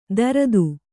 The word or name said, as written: ದರ್ದು